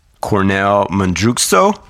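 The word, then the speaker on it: Kornél Mundruczó: It was directed by Cornel Mundruczó. – Very good pronunciation.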